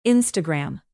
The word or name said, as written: instagram